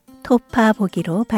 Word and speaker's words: 톺아보기: 따라서 읽을 때는 토파보다 토파보기로 발음합니다.